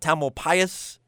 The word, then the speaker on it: Tamalpais: Mount Tamalpais.